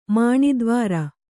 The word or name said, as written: ಮಾಣಿದ್ವಾರ